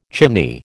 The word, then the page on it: chimney: American English